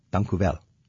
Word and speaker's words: Dank u wel: Dank u wel. – Alsjeblieft. – Did I say that right? – Yeah, you said perfect.